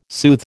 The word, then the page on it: soothe: American English